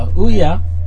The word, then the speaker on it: Ouya: So, Ouya, is that how you pronounce it? – Yeah.